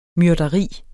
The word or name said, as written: MYRDERIERNES